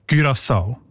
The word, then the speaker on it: Curaçao: Curaçao heisst die Insel, habe ich gelernt. – Sorry? – Mit Ü, genau.